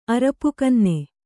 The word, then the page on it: ಅರಪುಕನ್ನೆ: kannada